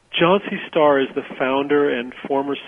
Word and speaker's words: Chauncey Starr: by name Chauncey Starr. Am I pronouncing it correctly? – Yes, yes.